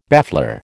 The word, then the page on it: baffler: American English